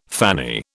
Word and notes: fanny: British English

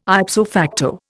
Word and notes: ipso facto: Indian

